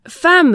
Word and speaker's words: femme: Femme, la lettre E suivie de deux M se prononce A comme dans femme ou évidemment.